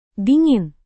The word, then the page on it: dingin: indonesian